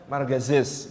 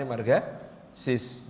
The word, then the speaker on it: Marga Sis: nama Arab Sif bin Kasan Besari tapi karena salah tulis pakai Z gitu ya sampai sekarang dibacanya Margazis gitu. Padahal itu aslinya Marga Sis bin Kiai Kasan Besari adiknya Kiai Mojo.